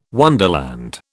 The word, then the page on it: wonderland: British English